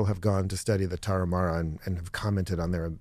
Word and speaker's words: Tarahumara: the Tarahuma. – Tarahumara.